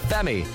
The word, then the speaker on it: F3Me: And you know our playlist is jam-packed with pure Irish talents at the moment, including this man whose name is spelt F3Me, but it's pronounced Femi.